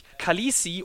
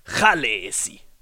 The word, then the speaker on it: Khaleesi: Tatsächlich ist der erste Laut ein R und der Vokal ist ein sehr langes E und die Betonung liegt auf der ersten Silbe. Also die Dothraki-Aussprache von Khaleesi ist Chalesi.